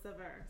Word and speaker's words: Saveur: It's called Savour Magazine. It's actually S-A-V-E-U-R. And it's really funny because the people who work there had a contest on how to pronounce the name of the magazine. So if you're really bored, you could look up, you know, how to pronounce it, and you're going to see a video at their Christmas party, and everybody's like, Savour, Savior, Savour. How do you say it? You know, so anyway, the consensus was Savour, so.